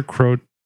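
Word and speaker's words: Croatan: Hidden in a live oak forest close to Pamlico Sound, Cape Creek was the site of a major Croatone. Did I say that wrong? – No, you said it right that time.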